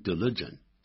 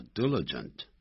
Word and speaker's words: diligent: 我们也可以采用形容词 diligent，不要念成 diligent，叫 diligent，也一样的造出相同意思的这种句构。